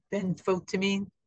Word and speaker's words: Benfotiamine: We talked about this before, but the benfotiamine form of thiamine is supposed to protect against nerve pain.